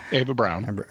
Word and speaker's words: Eva Braun: So Jason asks, from the perspective of Catholic theology and canon law, how would the church view Adolf Hitler's marriage to Eva Braun? Did I pronounce that correctly? – Yeah, Eva Braun. Braun.